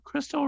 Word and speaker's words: Krystal: And it's KRYSTAL. Crystal Rihanna Waters is what my Facebook is.